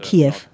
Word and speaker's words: Kiew: Ich habe nämlich Kiew russisch ausgesprochen. Russen sagen Kiew. Das ist aber nicht richtig. – Kiew. – Es ist Kiew, nicht Kiew. Russisch und Ukrainisch sind zwei verschiedene Sprachen, ungefähr so wie Deutsch und Niederländisch.